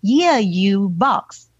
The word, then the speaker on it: YEAYUBOX: It is written as YEAYUBOX, and it's pronounced as, let me try, Yeah You Box, right? – Yeah.